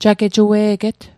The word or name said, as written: jagejue'get